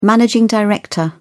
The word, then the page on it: managing director: British English